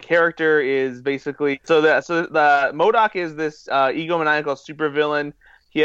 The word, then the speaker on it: MODOK: MODOK is one of those, one of the more obscure characters, if you will, from Marvel Comics.